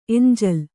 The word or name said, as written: ಎಂಜಲು